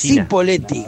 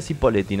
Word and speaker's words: Cipolletti: Porque si se escribiera CH, ahí sí diría Cipolletti, pero se escribe con C. – CH, y es Cipolletti.